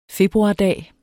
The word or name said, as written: februardag